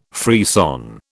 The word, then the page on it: frisson: British English